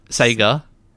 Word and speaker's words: Sega: Sega! Is that how you pronounce it? – Yes, that's how you're Sega. Supposed to pronounce it. No, I'm better than Sega. I say it Sega. I'm above the Sega peasants.